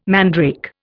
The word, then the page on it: mandrake: Indian